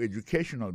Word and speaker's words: educational: Moi, j'ai 5 syllabes là-dedans. We'll be talking about your educational background. Alors, accent tonique, là, quand on peut poser plus là, il tombe sur troisième syllabe là.